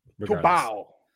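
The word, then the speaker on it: Tubarão: Tubara. Am I saying it wrong? It's a shark. – Tubau. – Oh, that's right. It's Brazilian. Tubau. They have a weird relationship with R's in Brazil.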